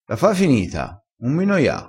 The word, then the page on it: noia: dialettale